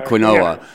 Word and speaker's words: quinoa: Yeah, quinoa.